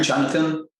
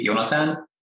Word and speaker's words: Jonatan: My the native pronunciation of my name is actually Jonatan, but in the US I usually go with the English pronunciation because that is easier for everyone.